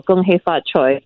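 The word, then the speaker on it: Kung Hei Fat Choi: Am I saying it correctly? Gung Hei Fa Choi? – Yeah, I was, you know, and I missed complimenting you. You said it very well. Gung Hei Fa Choi, yes.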